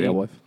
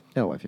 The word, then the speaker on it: Alewife: Or Alewife, however you pronounce it. – I think it's Alewife. – Alewife, yeah.